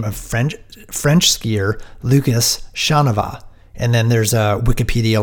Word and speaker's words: Chanavat: Quote, you are reversing the N and V and pronouncing it Chavanaugh, like it rhymes with Kavanaugh.